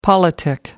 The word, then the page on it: politic: American English